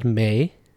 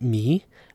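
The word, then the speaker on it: mi: In Afghanistan and Tajikistan, this is pronounced may and not me.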